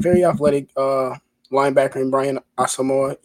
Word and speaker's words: Asamoah: So talk to me a little bit more about Brian Asamoah, I think is how you say it, Asamoah? – I think it's Asamoah. – Asamoah, oh, wow. Well, that was quite the bad pronunciation. My apologies.